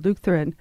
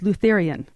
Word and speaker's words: Lutheran: For example, Lutheran was Lutherian. So you have a generation of students who say Lutherian.